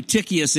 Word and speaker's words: Tychicus: Well, here's where some of us would check out and say, well, I don't know who this Tychicus is or even how to pronounce it. The C-H is like a K. So I don't know who Tychicus is, and so I don't know him.